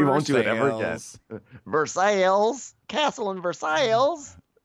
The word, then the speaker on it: Versailles: And we really had no way of knowing that Versailles, Kentucky, is pronounced Versailles, not Versailles.